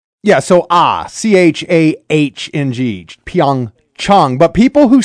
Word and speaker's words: Pyeongchang: The correct pronunciation is Pyeongchang. Chang, ah, ah, like when you go see your doctor, ah. Pyeongchang, Pyeongchang, Pyeongchang.